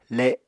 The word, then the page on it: le: apatani